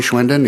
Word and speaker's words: Schwinden: For the record, Dory Schwenden, Executive Director for the Pension Systems at MPERA. My name is spelled first name D-O-R-E, last name S-C-H-W-I-N-D-E-N.